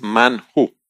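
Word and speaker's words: manhu (מן הוא): Yo estaba investigando y en hebreo, cuando eso se pronuncia, ¿qué es eso? Es manhu. O sí, manhu, sería así.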